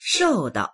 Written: shou4dao

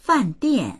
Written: fan4dian4

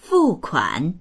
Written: fu4kuan3

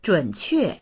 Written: zhun3que4

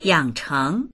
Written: yang3cheng2

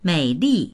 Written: mei3li4

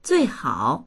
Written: zui4hao3

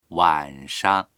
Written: wan3shang

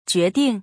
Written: jue2ding4

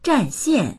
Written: zhan4xian4

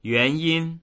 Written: yuan2yin1

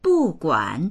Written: bu4guan3

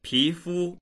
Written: pi2fu1

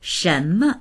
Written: shen2me

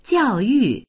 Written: jiao4yu4

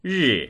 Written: ri4

